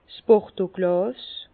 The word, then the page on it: 's Bordeauglàs: Bas-Rhin; Herrlisheim